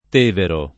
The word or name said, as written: Tevero